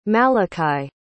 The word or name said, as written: malakai